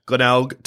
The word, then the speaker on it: Glenelg: It kills me to hear their song because I'm also a Glenelg Tigers supporter.